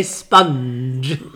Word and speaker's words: sponge: How do you say that? – Spong cake. I think you say sponge.